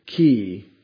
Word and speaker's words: chi: And again, we say chi, and you're supposed to kind of pronounce it like lach in Loch Ness, like ch.